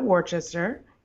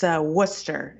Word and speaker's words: Worcester: It is not Worcester, even though it looks like it. It's Worcester. It's taken me a year to learn that, but it's Worcester. – So it's like I made a syllable violation there. It's not Worcestershire. – Yeah, you just ignore most of the letters in the middle.